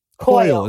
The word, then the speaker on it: Coyle: Yes. Coyle. – It's not Coily? Okay. – No.